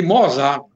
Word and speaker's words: Mozart: Aí, graças a Deus, o povo aqui não pronuncia tudo errado meu nome, chama de Mozart.